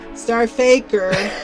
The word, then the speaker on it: STRFKR: I can't really say the name of it, but it's spelled S-T-R-F-K-R, star effer. – Star Faker. – It's Star Faker, right.